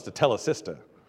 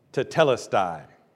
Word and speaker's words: Tetelestai: I've heard so many wonder how to pronounce the word. Somebody mentioned to me last week that someone thought it was to tell a sister. Tetelestai.